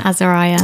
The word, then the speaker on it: Azariah: Azariah.